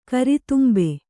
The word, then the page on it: ಗರಿತುಂಬು: gari tumbu